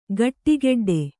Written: ಖಡಖಡಾಟ

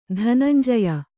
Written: ಧನಂಜಯ

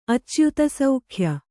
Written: ಅಚ್ಯುತಸೌಖ್ಯ

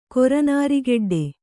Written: ಕೊರನಾರಿಗೆಡ್ಡೆ

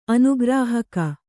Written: ಅನುಗ್ರಾಹಕ

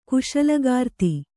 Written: ಕುಶಲಗಾರ್ತಿ

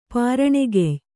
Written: ಪಾರಣೆಗೆಯ್

